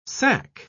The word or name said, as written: sacque